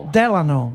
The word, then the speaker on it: Delano: No, va bene, dai, no, pronunciacelo bene, come si dice? – Delano. – Diciamo così. Franklin Delano Roosevelt, va bene.